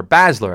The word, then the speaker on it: Baszler: Shayna Baszler, or Baszler, I should say, taking on Betch with an A, by the way.